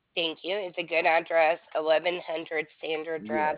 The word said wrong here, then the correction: Sandia: 1100 Sandia Drive. – Oh, yes. – I'm sorry. I didn't know how to pronounce it. It looked like Sandra to me.